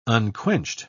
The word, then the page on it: unquenched: British English